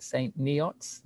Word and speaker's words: St. Neots: St. Neots, is that right? – Yeah.